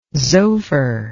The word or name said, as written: zophar